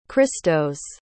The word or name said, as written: cristos